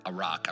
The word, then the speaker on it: arak: The word prepare there means, it's from the Hebrew word arak. I probably said that wrong, but A-R-A-K.